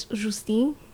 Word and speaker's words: Justine: I'm sorry, my name is French, but I pronounce it not the French way. Although, wouldn't that be great if one day I was just like, it's Justine? – Exactly.